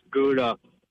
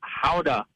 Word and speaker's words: Gouda: It's not pronounced Gouda. It's Howda.